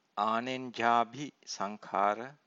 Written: anenjabhi sankhara

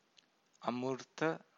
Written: amurta

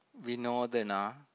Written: vinodana

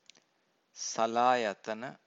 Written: salayatana

